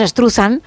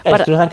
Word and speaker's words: Struzan: Bueno, pues Drew Struzan o Struzan o como cada uno lo quiera pronunciar. Nosotros decimos Struzan Estruzan, que es más fácil.